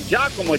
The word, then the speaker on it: Giacomo: You pronounce it right. Giacomo, G-I-A-C-O-M-O.